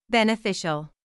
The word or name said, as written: beneficial